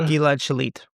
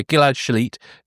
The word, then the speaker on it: Gilad Shalit: This kind of current deal slightly reminded me of the case of, and I want to hope I get his name right, Gilad Shalit, the IDF soldier. – Gilad Shalit. – Yeah, Gilad Shalit, the IDF soldier who was captured by Hamas and later released in exchange for a large number of Palestinian prisoners.